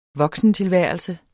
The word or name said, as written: voksentilværelse